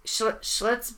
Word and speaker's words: Schlitz: Schlitz beer. – Am I saying that right? – Yeah, it sounds like the shits.